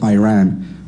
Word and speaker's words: Iran: You say you support the Iranian people, but you're pronouncing our country's name wrong. Our country is named Iran, not Iran. Please, once and for all, let's get this clear.